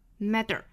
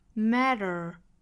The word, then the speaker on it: matter: 那如果发成了真正的这个的的发音，就变成了 matter， matter，那就不对了，应该是往后走，在上颚的部分轻轻的一弹就卷进去发卷舌音了， matter。 Matter, matter, matter, 一弹而过 matter。